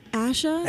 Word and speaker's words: Asha: It's Asha.